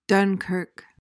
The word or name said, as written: dunkirk